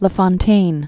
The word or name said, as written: La Fontaine, Jean de